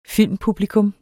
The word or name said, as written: filmpublikum